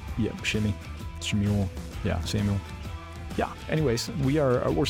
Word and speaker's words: Shmuel: Yep, we're in the book of 1 Shmuel. – That's good. I like that. Yeah, that's the Hebrew pronunciation.